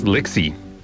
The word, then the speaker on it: Lixie: Yes, Lixie. Rhymes with pixie.